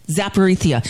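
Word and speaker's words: Zaporizhzhia: Zaporizhia.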